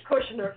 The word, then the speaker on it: Kushner: Kushner.